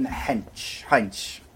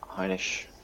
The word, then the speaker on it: Heinisch: Next up, middleweight bout between Kelvin Gastelum and Ian Heinz. Apologise if I pronounced his name wrong. You got there. – Heinisch.